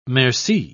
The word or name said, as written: merci